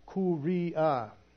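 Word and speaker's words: Kyria: And it's it phonetically, it's pronounced Kuria, Kuria. But it sounds more and looks more like Syria, C-Y-R-I-A.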